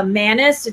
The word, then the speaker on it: Manis: The Manus. It's spelled Manis.